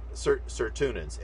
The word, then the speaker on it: sirtuins: Or serutins, I don't know if I'm pronouncing it right. It's serotonins, S-I-R-T-U-I-N-S.